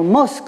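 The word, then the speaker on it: nomos (νομός): nos nomos, quand il est accentué sur la dernière syllabe, signifie aussi la pâture, le pâturage.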